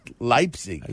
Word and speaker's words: Leipzig: L-E-I. The I is pronounced Leipzig.